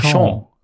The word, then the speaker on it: Auchan: Okay, wie spricht man? Adjutant Auchan? Auchan? Auchan. – Auchan würde ich schon würde aussprechen, ja.